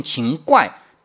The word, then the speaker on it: 秦桧: 在历史上有一个人留下了千古骂名，到现在还贵着呢啊，那就是秦桧儿。注意，秦桧这个读音是错的，虽然字典上这样标着，这是建国以后才出现的新读音，以前从来没有过。历史上读法接近秦怪，写作秦快。